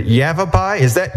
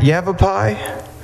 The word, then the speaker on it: Yavapai: It's the Yavapai. Did I say that right? Yavapai? – Yes, sir. You said it right.